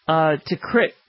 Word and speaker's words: Tikrit: So first Mosul goes down, the second largest city, and now Tikrit, really, is how you say it.